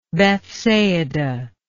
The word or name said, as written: Bethsaida